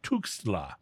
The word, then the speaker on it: Tuxtla: T-U-X? I'm sorry, I mispronounced it. It's Tuxtla.